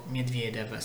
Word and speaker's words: Medvedev: Philippe Chatrier dalle 13.15 Alexander Bublik contro Danil Medvedev, Medvedev se preferite la pronuncia russa.